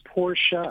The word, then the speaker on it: Porsche: What is the correct pronunciation? You run a Porsche dealership. – Well, it's somebody's name. It's Porsche.